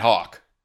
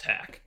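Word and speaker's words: Haack: I'm going to go with Matt Hawk, the punter, because why the hell not? – I still think his name should be pronounced Hack. – Well, I know that it's not, but I still think it's way cooler.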